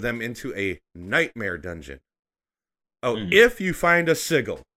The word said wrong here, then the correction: sigil: Or a sigil. Sorry, I don't know. I always pronounce the G hard.